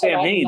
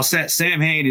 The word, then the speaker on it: Samhain: Well, Sam Hain is really pronounced Samhain. That's not the correct pronunciation of it, so I wouldn't count that. It's not a name. It's just Halloween in Gaelic.